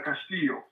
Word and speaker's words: Castillo: I pronounced your last name correct. – Uh, yes, it's uh, Castillo.